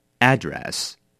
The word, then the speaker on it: address: 第一个，它当作名词，当名词我们比较常把它念成 address。